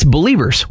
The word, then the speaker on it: BLVRS: B-L-V-R-S. It's Believers without the vowels.